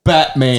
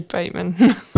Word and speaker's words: Batman: B-A-T-M-A-N. John Batman. – It's actually Bateman. – Batman. There's no E there. And I am sick of Victorians telling me otherwise. I have had an absolute gutful of going to Melbourne and being like, hey, that suburb's Batman. No, it's not. It's pronounced Bateman. Well, if it's pronounced Bateman, where's the E? B-A-T. Bat. M-A-N. Man. Batman. Come at me, Victoria.